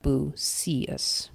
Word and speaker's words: Busias: And I think we're supposed to pronounce that Busias with the emphasis on the middle syllable.